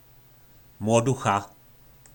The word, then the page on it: Mloducha: po nasimu